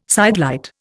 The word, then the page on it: sidelight: Indian